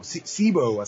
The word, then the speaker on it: Zebo: Sebo, así que se pronuncia. Sebo Temple. Es con Z. – Sebo, entonces. Sí. Z-E-B-O.